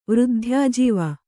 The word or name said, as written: ವೃದ್ಧ್ಯಾಜೀವ